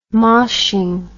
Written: moshing

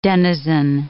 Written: denizen

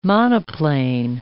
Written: monoplane

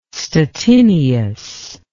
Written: Stettinius

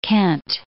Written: Kent

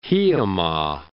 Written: Hiiumaa

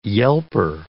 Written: yelper

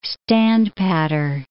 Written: standpatter

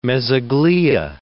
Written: mesoglea

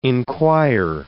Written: inquire

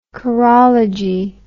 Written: chorology

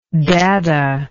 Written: daddah

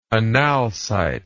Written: analcite

